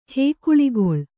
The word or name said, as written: ಹೇಕುಳಿಗೊಳ್